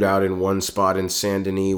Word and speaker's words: Saint Denis: It's spelled out Saint D-E-N-I-S, but it's pronounced Saint Denis. – Oh, okay. – So it's more of a French pronunciation.